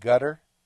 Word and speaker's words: Qatar: Some people pronounce it gutter.